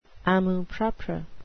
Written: amour-propre

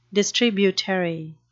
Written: distributary

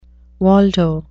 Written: waldo

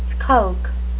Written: skulk

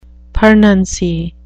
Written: pernancy

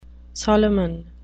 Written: solomon

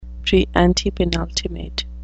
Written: preantepenultimate

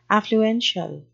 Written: affluential

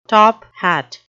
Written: top-hat

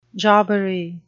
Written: jobbery

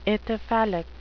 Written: ithyphallic

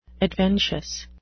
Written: adventious

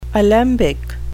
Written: alembic

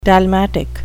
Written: dalmatic

